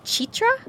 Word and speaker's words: Citra: I think it's actually pronounced Chitra, because it's C-I-T-R-A,